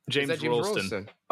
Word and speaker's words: James Rolleston: James Rolleston.